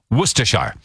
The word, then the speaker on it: Worcestershire: I now know how to say the word Worcestershire properly. Apparently it's Worcestershire.